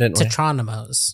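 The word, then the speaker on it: tetrominoes: This jigsaw puzzle one, which for people who haven't done this, it was like there were tetraminos. Am I saying that right? – Yeah, I think people also pronounce it tetronymos.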